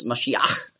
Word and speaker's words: Maschiach: Also darum wurde aus Masch, wurde Mess und dann Iach mit dem Het am Schluss, Maschiach, hat man früher ausgesprochen, Maschiach. Da hatten sie auch Mühe und darum haben sie daraus gemacht Messias.